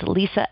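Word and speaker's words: Lisza: So my first name is spelled L-I-S-Z-A, L-I-S-Z-A, and it's Lisa L. Crisalli, C-R-I. S-A-L-L-E.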